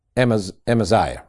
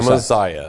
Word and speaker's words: Amaziah: You found it where somebody phonetically, yeah, it's Amaziah, right, is what we said. – Amaziah.